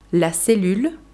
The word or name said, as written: la cellule